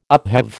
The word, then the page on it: upheave: American English